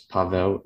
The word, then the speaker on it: Paweł: So you can, it's Paul is fine, but the proper pronunciation is Paweł. It's actually Polish.